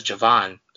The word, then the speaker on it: Javon: So I think the only other one that we've gotten wrong so far, the pronunciation for Javon, it says Javon. It's J-U-H and then V-O-H-N. Obviously, that's not how he spells it, but that is the pronunciation.